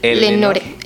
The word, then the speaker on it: Lenore: Si, si pueden encontrar más sobre esto, pues ahí googling Leonor. ¿Eleonor o Leonor? – Le, se escribe Lenor. Lenore. Lenore.